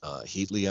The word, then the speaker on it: Heatley: There has been some discussion that maybe the first line will have Heatley out there.